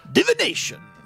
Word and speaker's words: Divination: And it is time for division. I think that says. – Divination. – Yeah, which... – Don't just go, yeah, you said it wrong twice.